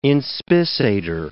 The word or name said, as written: inspissator